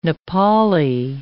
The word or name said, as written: Nepali